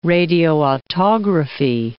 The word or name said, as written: radioautography